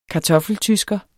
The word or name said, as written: kartoffeltysker